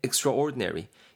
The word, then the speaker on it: extraordinary: 그 앞에 extra, i, extra가 온거예요 원래 extra로 발음이 되는데 ordinary랑 붙으면서 약간 extraordinary 이렇게 발음이 되고 있고요.